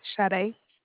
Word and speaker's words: Sade: Sade. It's spelled S as in Sam, A as in Apple, D as in Dog, E as in Edward.